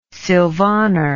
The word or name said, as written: sylvaner